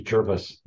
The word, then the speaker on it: Gervase: Yeah, my name is Gervase, as in nervous. Gervase. – Okay, thank you. – Gervase Bushe, and I'm the professor of leadership and organization development at the BD School of Business, Simon Fraser University in Vancouver, Canada.